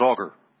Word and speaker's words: Agur: It says Agur, but it's pronounced Augur.